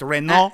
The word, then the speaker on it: Renault: I appreciate you pronouncing it Renault, by the way, because the French just get rid of letters and it fucking drives me nuts. Fuck him. There's a T there. Okay. – It's A L U T, and they say we pronounce A L U T, even though there are all these letters. We call it O. – And Renault. 17. – It's Renault.